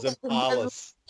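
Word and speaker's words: Zempilas: That's how they say it over in Perth, Zimpalis.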